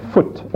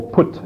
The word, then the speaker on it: Phut: It says, and the sons of Ham, Cush, and Mizraim, and Phut, or Put, as you can pronounce it too, and Canaan.